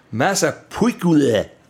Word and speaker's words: Massapequa: I think it's Massapequa. – Oh, yeah, perfect.